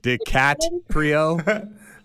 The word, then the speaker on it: DiCaprio: DiCaprio? – did I pronounce his name correct? – You did.